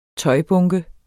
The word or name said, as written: tøjbunke